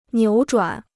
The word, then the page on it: 扭转: niǔ zhuǎn